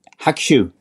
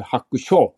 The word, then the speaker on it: Hakushu: Det här är språkpolisen från Japan och eh, Hakushu är helt korrekt uttal. Så du sa rätt i detta avsnittet David, helt riktigt. Och det du spelade upp var också helt riktigt givetvis. Eh, I förra avsnittet sa du Hakusho och det var lite det jag...